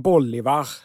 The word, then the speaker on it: Bolivar: Simon Bolivar, ja, det tror jag han ska heta som i boll.